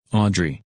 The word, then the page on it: Audrey: English